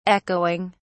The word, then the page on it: echoing: British English